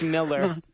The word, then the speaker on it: Millar: You got to pronounce his name right. – It's Miller. – Bitch. – Nothing. I have heard the guy pronounce it himself.